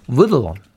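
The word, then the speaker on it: Wiedlin: 네, W-I-E-D-L i n 이라고 되어 있어서 발음 알려주는 사이트에 들어가서 쳐봤는데 위들린으로 발음을 저는 했는데 예. 미국 분들은 위들론, 위들론 이렇게 발음을 하시더라고요.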